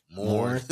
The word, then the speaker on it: mourn: But, you know, let people celebrate or moan, well, how you say it? – Mourn. – Excuse me.